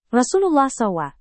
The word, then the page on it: rasulullah saw: Indonesian